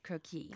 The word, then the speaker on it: croquis: I believe that the accent is on the croquis. – Second. Croquis. – Croquis. – Is on the second.